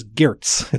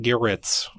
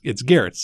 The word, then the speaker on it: Gertz: It is Gertz. It's one syllable, not Garrett's. Whatever some of my cousins might say, it's Gertz.